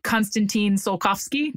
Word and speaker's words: Konstantin Tsiolkovsky: Konstantin Tsiolkovsky.